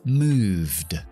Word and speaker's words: moved: Bien, si el verbo termina con un sonido sonoro, es decir, si mi voz vibra hasta el final de la palabra, vamos a pronunciar una D, únicamente la D, la E no. Es decir, como en el verbo play, played, o el verbo moverse, move en pasado, moved. No pronuncio la E, pronuncio la D porque va después de la V, que V es sonoro.